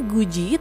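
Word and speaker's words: guji (굳이): Nah, ini pelafalannya guji tapi penulisannya itu hati-hati ya. Ditulisnya itu dari suku kata gut. Gutnya itu terdiri dari giok, u dan juga diget ya. Huruf diget sebagai bacim atau konsonan akhir dari satu suku kata ini, lalu setelahnya diikuti dengan suku kata berupa huruf ieng dan juga i.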